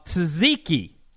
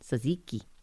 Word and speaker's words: tzatziki: I think the Greek dip Vinny might be talking about is called tzatziki. – Tzatziki. – Tzatziki. Is that how you say it? – Something like that.